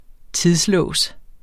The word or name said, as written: tidslås